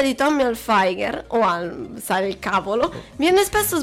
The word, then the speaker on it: Hilfiger: ha mantenuto il suono duro della lettera G, che si pronuncia come se fosse seguita dalla lettera H, Hilfiger.